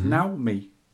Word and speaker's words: Naomi: This is the Sega Naomi, not pronounced Naomi, or is it? – Don't know. – It may well be pronounced this way, according to Japanese pronunciation, which is very annoying, as I've been ranting at a certain Dreamcast Junkyard co-host who lives in Japan. I reckon he might be right, because it's pronounced Naomi. And if he lives there, he obviously hears people speaking about it, he's got to be right. And even wife said it's pronounced that way.